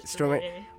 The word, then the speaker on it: Stromae: Stromae.